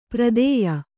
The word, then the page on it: ಪ್ರದೇಯ: pradēya